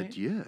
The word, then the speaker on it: adieu: With that, we bid you adieu. – Ooh, French. Nicely done. – Did I say that right? – Adieu, yeah. Adieu.